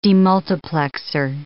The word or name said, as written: demultiplexer